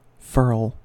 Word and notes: furl: American English

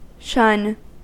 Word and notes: shun: American English

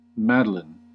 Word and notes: madeleine: American English

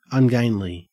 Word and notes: ungainly: Australian English

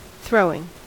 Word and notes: throwing: American English

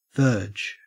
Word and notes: verge: Australian English

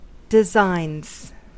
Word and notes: designs: American English

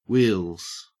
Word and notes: wheels: Australian English

